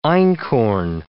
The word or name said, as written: einkorn